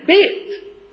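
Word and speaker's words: بيت (בית): حرف الباء في اللغة العبرية بيت، بيت الكلمة نطقها بالعبري بيت، ومعناها بالعبري وبالعربي بيت.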